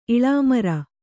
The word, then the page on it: ಇಳಾಮರ: iḷāmara